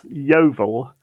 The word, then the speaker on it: Yeovil: And how do you pronounce the name of your town? Did I say it right? – It's Yeovil.